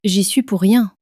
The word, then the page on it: j'y suis pour rien: French